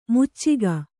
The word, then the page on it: ಮೋಚಿಕೆ: Kannada